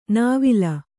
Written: ನವಿಲೆ

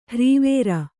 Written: ಹರವರಿ

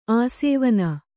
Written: ಆಸವನ

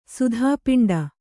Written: ಸುಧಾಪಿಂಡ

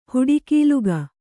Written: ಹುಡಿಕೀಲುಗ

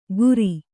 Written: ಕುರಿ

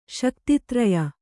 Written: ಶಕ್ತಿತ್ರಯ

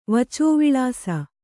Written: ವಚೋವಿಳಾಸ

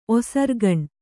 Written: ಒಸರ್ಗಣ್ಣಿ

